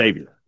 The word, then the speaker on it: Xavier: the X is spelled is pronounced like a Z. – Yeah. – Xavier.